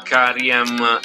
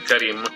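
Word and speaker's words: Karriem: Voglio farvi infatti ascoltare un brano di un musicista, batterista jazz, ma anche produttore hip hop e DJ, che si chiama Karim. Scusate, l'ho letto un po' come è scritto, ma in realtà la pronuncia è Karim.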